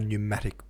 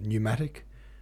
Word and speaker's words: pneumatic: You know, like a pneumatic pump, which is spelled P-N-E-U, but it's pronounced pneumatic, not pneumatic.